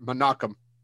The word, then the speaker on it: Menahem: Menahem Golan. – It's actually pronounced, I'm so sorry. I'm not actually doing it to be a dick because it took me forever to get it. Menachem.